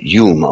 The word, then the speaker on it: Home: nämlich Daniel Douglas Hume oder Home, eigentlich Hume ausgesprochen war in Schotte, streng wissenschaftliche Experimente machte, dann von der Echtheit und Wahrhaftigkeit der Phänomene überzeugt war, sich hat hineinführen lassen in das Thema.